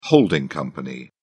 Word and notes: holding company: British English

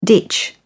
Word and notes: ditch: British English

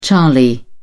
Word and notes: charlie: British English

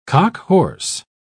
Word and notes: cockhorse: British English

